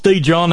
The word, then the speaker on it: Dijon: Dijon, yeah, it's Dijon. That's how we pronounce it here in the hills.